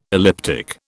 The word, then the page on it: elliptic: British English